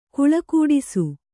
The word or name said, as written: ಗೋಳುಗುಟ್ಟಿಸು